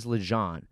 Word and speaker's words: Legendre: The dude, the dude, his his real name is Lejean, but it's spelled Legendre, and then he gets Legendre, he gets fucking pussyhead, like he gets all sorts of shit, right?